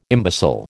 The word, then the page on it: imbecile: American English